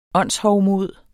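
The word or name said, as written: ÅNDSHOVMOD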